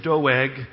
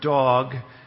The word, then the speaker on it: Doeg: His name is Doeg. You might pronounce it dog, and he turns out to be a dog, as we'll see in the verses ahead.